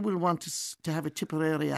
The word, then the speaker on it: Tipperary: Yeah, it's going to, Tipperary going to take over the world, Marty. I think that's the way it's going to be. – Tipperary people pronounce it with no E.